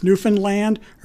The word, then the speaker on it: Newfoundland: We were told ahead of time, you always pronounce land at the end. It's Newfoundland or Newfoundland. Either okay. one is acceptable, but locals, not Newfoundland.